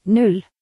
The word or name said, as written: 0